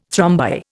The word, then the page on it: thrombi: Indian